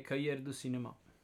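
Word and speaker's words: Cahiers du Cinéma: Cahiers de Cinema nell'aprile del 1957. Sai pronunciarlo meglio? Direi Cahiers de Cinema.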